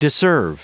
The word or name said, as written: disserve